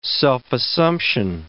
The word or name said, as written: self-assumption